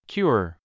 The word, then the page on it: cure: kjúə r; キュア